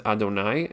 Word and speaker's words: Adonai: So the name of Hashem, the four-letter name, we don't read it the way that it's spelled. It's spelled with a yud and a hey and a vav and a hey, which seems to have the root of that as something to do with the word havaya, which literally means reality or existence. We actually pronounce that name the Shem Adnut, it's called, and the word is Adonai, and that word basically means my master.